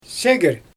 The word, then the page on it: segr: Xyzyl